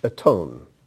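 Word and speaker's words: Aton (אתון): I uh, was checking out the word for donkey here, and in Hebrew it's spelled, let me get a pen, it's spelled Aleph Tav, Vav, Nun. Aton. Aton.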